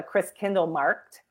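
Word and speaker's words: Christkindlmarkt: So it's called a Chris Kindle Markt, and that's it's a German word, right?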